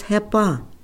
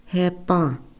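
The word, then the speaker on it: Hepáŋ: Now when we have a secondborn boy into the family, that comes into our families and our Tiwahes, he is Hepa, Hepa. So let's pronounce that together, Hepa, Hepa.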